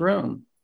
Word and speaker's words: ruwm: But for instance, in Proverbs 6, verse 17, there where he hates a proud look, the word in the Hebrew is 7311, R-U-W-M. And it looks like it's pronounced room, to be high, to rise, or raise up.